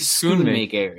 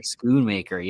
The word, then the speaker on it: Schoonmaker: Oh man, see, we looked it up before the show to know how to say his last name, and now I forget. I think Schoonmaker. – Schoonmaker, yes, which sounds like a draft beer, so I always call him a draft beer.